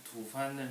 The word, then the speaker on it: 吐蕃: 我们之前提到吐蕃帝国土吐蕃的这个历史，然后我们知道很多人都把这个字读作吐蕃，那我们今天呢都读作吐蕃，那是为什么？